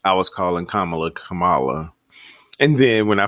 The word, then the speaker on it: Kamala: But yeah, Kamala, damn it, Kamala.